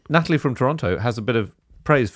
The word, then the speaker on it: Toronto: We do, in fact, pronounce the city Toronto.